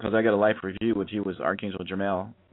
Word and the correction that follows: Jeremiel: I mean, mm-hmm. Jeremel. I'll spell it for you, okay? It's spelled yeah. Jeremiel.